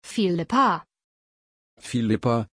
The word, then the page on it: phillippa: German